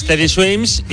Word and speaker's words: Teddy Swims: Se llama, a ver si lo digo bien, Teddy Swing. – Teddy Swims, y esta es una canción que hace con Tiago, la hemos puesto en algún otro.